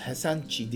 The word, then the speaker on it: Resende: Şimdi son parçamız Marcus Resende diye yazılıyor ama aslında Marcus Hesenci diye okunuyor.